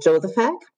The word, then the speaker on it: Jozefak: Heute sitzt mir digital zugeschaltet gegenüber Paul Josephak.